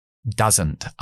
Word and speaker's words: doesn't: Doesn't. Ahora en la negación, como se acentúa esta palabra, entonces vamos a pronunciar a. Da. La tienda de campaña.